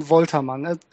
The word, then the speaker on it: Woltermann: Sorry. Fabian Woltermann. It's a German pronunciation. – Oh, it's a little bit different.